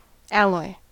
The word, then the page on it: alloy: American English